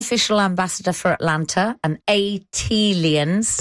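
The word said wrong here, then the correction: ATLiens: That is pronounced A-T-Aliens. – Ah, thank you. – Like Atlanta alien, A-T-Alien.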